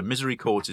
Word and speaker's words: misericords: So Misery Chords is spelled MISERICORDS.